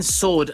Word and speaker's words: sword: At the W in sword, again, I can just about accept that one. Just about gets a pass for me. I don't know about you. – Sometimes it's pronounced sword. – It's like a nearly. It's nearly not silent. – I think that's one of those that it would have been. It would have been pronounced a lot more back in the day.